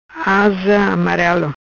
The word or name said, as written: Asa Amarelo